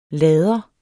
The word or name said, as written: lader